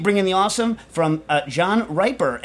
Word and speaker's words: Riper: And it's because, it's spelled R-I-P-E-R, so there's a question. And as everyone knows, I'm horrible at pronouncing names. So he hopefully added in parentheses, pronounced like the adjective, a riper apple beats an unripe one.